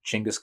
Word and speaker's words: Chinggis: You could, you know, I think probably my favorite possibly minor characters is Genghis Khan. although I think it might now be more properly pronounced Chinggis Khan.